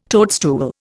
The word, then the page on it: toadstool: Indian